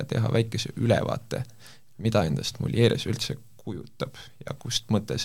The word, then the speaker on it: Mulieres: täna on meil külas Merilin Rüütli ja tema tuleb meile Eesti ökopuhastusvahendite tootjast Mulieres. kas me hääldasime seda õigesti? – ja hääldasite, tere!